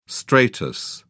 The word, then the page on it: stratus: British English